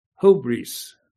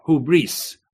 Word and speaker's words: hubris: Or hubris, hubris. The emphasis on the who, hubris.